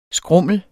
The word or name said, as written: skrummel